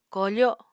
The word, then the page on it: kofo: Apatani